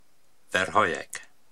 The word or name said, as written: Verhoyek